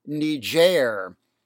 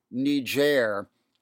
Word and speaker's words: Niger: I have been admonished in certain quarters to use the French pronunciation Niger, which I failed to do on our last podcast discussing the crisis in the country on August 12th.